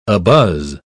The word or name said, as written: abuzz